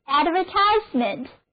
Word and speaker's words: advertisement: 我们做出来的这个广告就是在 advertise 后面加上 ment 的名词后缀，所以 advertisement 就表示广告是一个名词，重音后移了，单词就读作 advertisement。